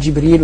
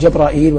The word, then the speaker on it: جبريل: وقد ورد في نطقه 10 أقوال. نعم. يعني في الاسم يقال جبريل ويقال جبرائيل ويقال جبرائيل وكلها واسعة.